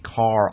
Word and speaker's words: Kahr: David Cohn is identified in a lawsuit against the gun company Carr Arms, that's spelled K-A-H-R, as the president of Carr's Moon-owned parent company, Salo, whose website advertises, quote, absolute concealed power, unquote.